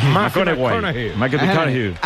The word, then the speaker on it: McConaughey: ¿Matthew McConaughey? – Sí, sí, sí, sí. – Hay que ir corrigiendo la pronunciación. – McConaughey.